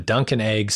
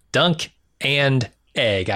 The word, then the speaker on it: Dunk and Egg: And then another spinoff, which I think you're going to be excited about, is the Duncan Egg spinoff. Not Duncan Egg, Dunk and Egg.